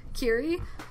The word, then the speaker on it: kiri: but in Japanese it's kitty, it's kitty, like, kitty, kitty, kitty, kitty, kitty, kitty, kitty, kitty, kitty, kitty, kitty. – Yeah. – It's K-I-R-I is deeper, so it's kiri, kiri,